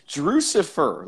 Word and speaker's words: Drucifer: What is the correct pronunciation of your name? – You claim that you've listened to our show, and it's Drucifer, like Lucifer.